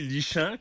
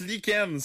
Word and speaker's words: lichen: Quelque chose qui se lit lichant, comme disait Marion. – Ben ouais, tu... – Pourquoi on le prononce lichen? C'est quoi le rapport? – Ça devrait être écrit comme L-I-K-E-N-N-E.